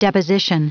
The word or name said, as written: deposition